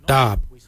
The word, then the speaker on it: dhaab: Today we will look at, for knife we say dab. Dab is spelled D-H-A-A-B.